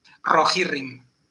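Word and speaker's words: Rohirrim: Entonces, pues normalmente decimos rojirrim, con la primera R fuerte.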